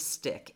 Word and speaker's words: Stikk: It is referred to as Stick. It's spelled S-T-I-K-K.